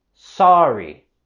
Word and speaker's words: sorry: En la palabra sorry, queremos pronunciar la primera sílaba como sar, sar. No so, sino sorry, sorry.